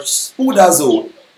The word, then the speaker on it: spoudazo: Is the Greek word spoudazo. Spoudazo is spelled S P O U D A Z O or spoudazo as you may like to mm.